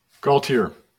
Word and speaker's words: Gaultiere: Gaultiere.